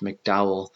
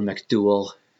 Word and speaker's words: MacDougall: and this is just another way of pronouncing MacDougall with MacDowell or MacDual.